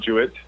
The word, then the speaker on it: Jewett: It's spelled the same way as mine, where the French way has an extra E on it. – Mm. This is a dilemma. Which one would you rather? – I'll go with Jewett, just so it's easier. – Right, the hard J.